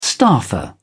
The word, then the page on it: staffer: British English